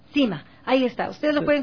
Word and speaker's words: SYMA: Cima Ecuador, se escribe la S-Y-M-A.